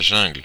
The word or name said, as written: jungle